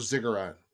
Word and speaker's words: ziggurat: But let's talk about that, because people know ziggurat, you know, rhymes with cigarette.